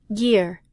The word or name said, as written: gear